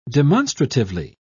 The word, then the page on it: demonstratively: British English